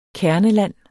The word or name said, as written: kerneland